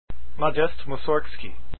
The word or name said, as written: Mussorgsky